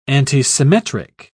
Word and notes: antisymmetric: British English

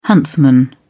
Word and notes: huntsman: British English

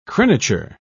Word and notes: crenature: British English

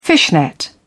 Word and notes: fishnet: British English

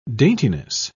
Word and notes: daintiness: British English

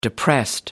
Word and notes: depressed: American English

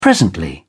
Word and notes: presently: British English

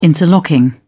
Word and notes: interlocking: British English

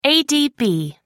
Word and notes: adb: American English

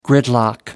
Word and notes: gridlock: American English

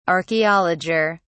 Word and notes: archaeologer: British English

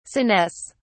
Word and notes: senesce: British English

